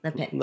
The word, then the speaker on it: Lapin: I say Pierre. – Lepin. – Lepin. – That means rabbit.